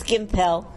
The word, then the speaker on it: Gimpel: It's Gimpel.